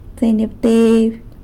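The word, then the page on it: tseinyabteby: San Lucas Quiaviní Zapotec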